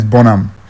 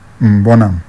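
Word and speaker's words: Burnham: His name is B-U-R. N. That's Burn, yeah? Then H-A-M. And you know British people, they are H-A-M. They know they pronounce the H. That's why you have Tottenham. they throw away the H. Nottenham. They throw away the H. So it's the same way as Bonham. Bonham.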